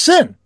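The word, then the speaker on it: Seine: Well, Sin, it's S-E-I-N-E. – Oh, that's what I thought. Okay. But you called it Sin. – Yes. It's pronounced Sin. That's the way you pronounce it.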